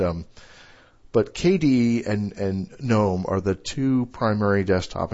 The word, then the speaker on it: GNOME: and it's actually called GNOME, you know, that hard G, like GNU.